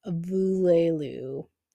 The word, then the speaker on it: voulezloux: The next fic is called This Love's a Luxury by Local Lopa on AO3, and his Tumblr is Voulelou, although it's spelled V-O-U-L-E-Z-L-O-U-X.